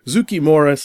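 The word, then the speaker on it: Zoukei-Mura: Zukimura spelled Zoukei. And the second word is Mura.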